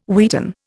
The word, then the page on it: wheaten: Indian